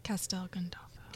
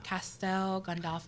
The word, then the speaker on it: Castel Gandolfo: Did I say that right, Italiano? – Castel Gandolfo. – Castel Gandolfo. Castel I always Gandolfo. Think of Gandalf.